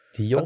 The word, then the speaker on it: Zeus: Att Zeus egentligen uttalas Dios. Att i det här med u som ska vara som V-ljud.